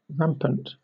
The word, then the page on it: rampant: British English